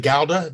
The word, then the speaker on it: Gouda: You pronounce it Gouda? – The Dutch would pronounce it like K-H-O-W-D-A, Gouda. – Gouda. Uh-huh. Gouda. And of course, Gouda. And as an American, I always have called it Gouda, but you can see, boy, a thick rind on that and a whole different characteristic.